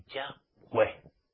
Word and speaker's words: Yahweh: Hoy en día los llamados mesiánicos de pacotilla, que son cristianos disfrazados de mesiánicos, en vez de pronunciar el nombre de Yahweh, YHWH, Yahweh nos dice que pronunciemos su nombre, Yahweh.